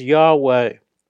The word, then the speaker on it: Yahweh: into the Hebrew tetragrammaton YHWH, phonetically sounded as Yahweh.